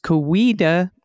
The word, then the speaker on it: Coweta: Coweta County is pronounced Coweta. Oh, I got it right. – You got it right. I got it wrong. – I wasn't even there. Coweta County, with the emphasis on the long E's.